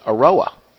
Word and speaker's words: Eiroa: Because there's no information on his own website that tells about his own cigar, which is Eiroa, E-I-R-O-A, his last name. Do I say it right?